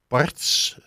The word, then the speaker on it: Barts: Vandaag is onze gast Arno Verkleij van Barts, zeg ik het goed? – Dat zeg je helemaal goed.